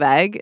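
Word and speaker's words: Eigg: It's spelled E-I-G-G. I lumped it together. – Oh, Isle of Egg. – It's not the Isle of Egg. It's the Isle of Egg. It's real.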